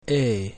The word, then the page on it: E: Latin